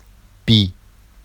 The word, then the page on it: bi: Vafsi